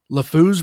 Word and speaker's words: LeFou: It was LeFou's Brew. I am saying his name right, correct? – Yes.